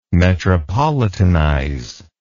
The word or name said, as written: metropolitanize